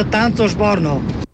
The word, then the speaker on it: Tanțoș Borno: Păi, normal se pronunță Tanțoș Borno. Tanțoș. Tanțoș. Tanțoș? Deci nu Cioș, Tanțoș.